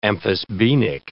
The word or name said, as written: amphisbaenic